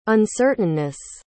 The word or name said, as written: uncertainness